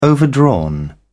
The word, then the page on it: overdrawn: British English